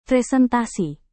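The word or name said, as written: prihatin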